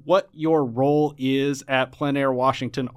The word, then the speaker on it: Plein Air: And for those of you who have been listening to this show, you might have heard me pronounce it Plein Air, but it is Plein Air Washington Artists, P-L-E-I-N.